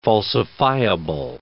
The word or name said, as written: falsifiable